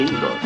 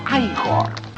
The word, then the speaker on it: Igor: Frankenstein, usted debe de ser Igor. – No, se pronuncia Igor.